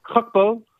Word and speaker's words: Gakpo: Gakpo.